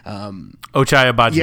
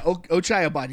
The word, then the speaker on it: Ochai Agbaji: And so the only way he could have done better defending, I can't ever say his name correctly, but Ochai Agbaji. – Yeah, Ochai Agbaji.